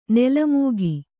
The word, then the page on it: ನೆಲಮುಕ್ಕು: nela mukku